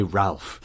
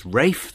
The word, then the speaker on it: Ralph: I am going to say Ralph. If people want to pronounce their names Rafe, they should spell them Rafe.